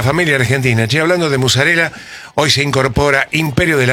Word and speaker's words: mozzarella: Mozzarella. – Sí, a ver. – Mozzarella. En el barrio es más con ese.